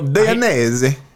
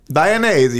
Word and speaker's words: Dainese: Never heard the proper pronunciation of that brand. Italian. Yeah, he just said it. I know. Yeah. It took me a split second to go. – Dainese. – Yeah. – Dainese. You got to do it with your hand out.